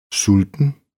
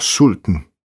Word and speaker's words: sulten: Mange gange er det kun stødet, der viser, hvilken betydning et bestemt ord har, som for eksempel i ordene sulten, hungrig udtales uden stød, og sulten, hungeren, udtales med stød.